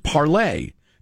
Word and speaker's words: Parler: Parler is French for to speak, pronounced parlay, as in parlez-vous français. But then this writer spells parlay, L-E-Z, as it is spelled.